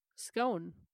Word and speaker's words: scone: And so if you look at the map, and we'll put it up on Instagram, you can see that the more north you go, the more that it's rhymes with gone. The south? – Scone.